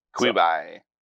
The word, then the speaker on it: Quibi: On the new liberal zoo-sounding streaming service Quibi. I think that's how it's pronounced. – Quibi.